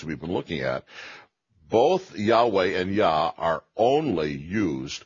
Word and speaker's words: Yahweh: And because of this, the Jews refused even to pronounce it, and its very pronunciation for that reason is now uncertain, although it is normally pronounced by scholars today, Yahweh or Yahweh, depending on how you take the Yod and the Vav and pronounce them in Hebrew.